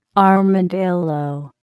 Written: armadillo

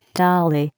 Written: dolly

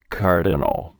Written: cardinal

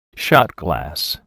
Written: shot glass

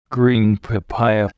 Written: green papaya

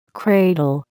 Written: cradle

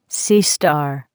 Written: sea star

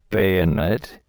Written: bayonet

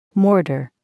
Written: mortar

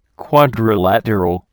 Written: quadrilateral

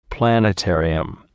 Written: planetarium

